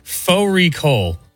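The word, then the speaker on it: fårikål: Faux Recall, which is a mutton and cabbage. It's spelled like Fari Call.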